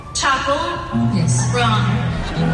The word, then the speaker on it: Chappell Roan: Ella misma nos explica cómo se pronuncia. – Chappell Roan. – Chappell Roan. Ah, pues lo digo mal yo.